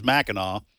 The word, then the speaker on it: Mackinac: So you're saying it is Mackinac. That's how they pronounce it. – That's how it's pronounced.